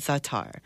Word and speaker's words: za'atar: So I can't I looked it up, and it's spelled zitar, and it's called za'atar. – Yeah, za'atar, I think, is how they pronounce it.